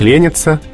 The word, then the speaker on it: ленится: В данном случае ударение перешло на корень. То есть ученик ленится, если их несколько, они ленятся, а если надо его спросить, что ты делаешь и зачем ты это делаешь, что ленишься.